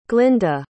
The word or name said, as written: glinda